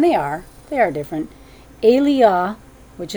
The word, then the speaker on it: Elijah: So Elijah is Eliah. That's from the Strongs.